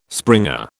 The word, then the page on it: springer: British English